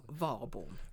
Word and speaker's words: Warborn: Det uttalas... Warborn. – Warborn, men det stavas W-A-R-B-O-R-N. Warborn.